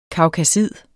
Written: kaukasid

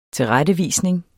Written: TILRETTEVISNINGERNES

